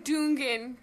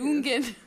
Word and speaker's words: Dungen: And we're saying Dungen because I didn't know how to pronounce it once, and I just sort of guessed, and then Hans Claus called me. – Really? – And said that my pronunciation was perfect. – Really? – So we're sticking to it.